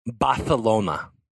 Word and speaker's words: Barcelona: And then Let's say it correct. Let's yes. say it correctly. Barcelona. – Barcelona. Gracias for the correct correction.